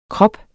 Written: krop

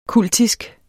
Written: kultisk

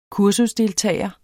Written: kursusdeltager

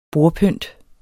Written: bordpynt

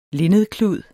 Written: linnedklud

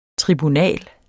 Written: TRIBUNALERNES

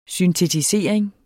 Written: syntetisering

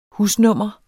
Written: husnummer

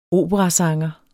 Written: OPERASANGERE

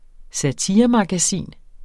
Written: satiremagasin

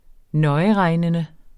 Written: nøjeregnende

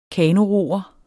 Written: kanoroer

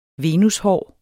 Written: venushår

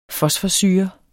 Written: fosforsyre

